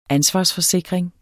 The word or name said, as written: ansvarsforsikring